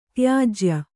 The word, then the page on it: ತ್ಯಾಜ್ಯ: tyājya